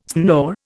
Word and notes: snore: Indian

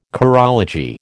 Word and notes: chorology: American English

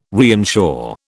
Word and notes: reinsure: British English

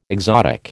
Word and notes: exotic: American English

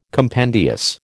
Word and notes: compendious: American English

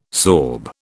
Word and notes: sorb: British English

